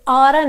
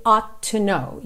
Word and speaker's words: oughta: Aqui a gente tem esse ought to, que é um verbo modal, que normalmente a gente vai pronunciar oughta, you oughta know, ao invés de you ought to know, you ought to know.